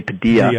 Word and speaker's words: Padilla: That's Danny Padilla for most people. I've always called him Padilla, but his name's pronounced Danny Padilla.